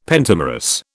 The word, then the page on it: pentamerous: British English